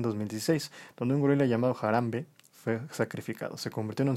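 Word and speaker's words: Harambe: No sé si se pronuncia así, pero jarambí Jarambe. Jarambe. – Es el gorila. Ok, ajá, correcto.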